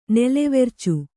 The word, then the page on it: ನೆಲೆವೆರ್ಚು: nelevercu